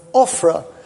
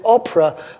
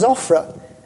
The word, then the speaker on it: Ophrah: I was very interested because the first time that word comes up in the reading, David pronounced it Oprah. And I was going to say to him very cleverly, that's somebody else entirely, as dear old Oprah went. But the second time round, he got it right.